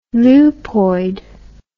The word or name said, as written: lupoid